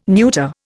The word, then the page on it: neuter: Indian